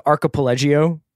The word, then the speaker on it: archipelago: By the way, I think that you just made that word Italian. – What? How do you pronounce it? – I think it's archipelago, but you sort of like were thinking of the Voltaggio brothers.